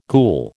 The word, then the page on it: ghoul: American English